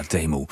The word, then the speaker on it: Temu: Ik denk maar dat we het zo gaan uitspreken, hè? – Ja, is helemaal goed. – Ja, Temu.